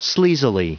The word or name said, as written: sleazily